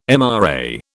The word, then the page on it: MRA: British English